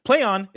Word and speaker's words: Plaion: Play on is pronounced exactly as you would expect, like, quote, play on, a phrase.